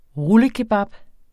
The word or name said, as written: rullekebab